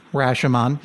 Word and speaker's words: Rashomon: I'm not sure how to say this. Rashomon? – Rashomon. Yes, you said it exactly right.